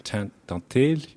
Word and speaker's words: Tantely: Okay, my name is Tantely, which is originally from Madagascar. So the right way to pronounce it over there would be Tantely Rahobisoa.